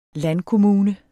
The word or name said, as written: landkommune